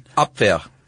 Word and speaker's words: Abwehr: The Abwehr, I think that's how you pronounce it. You're the one who knows German. – Abwehr.